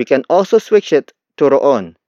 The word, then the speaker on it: roon: The next word is roon. Roon. Roon. Roon is spelled as R-O-O-N.